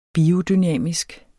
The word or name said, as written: biodynamisk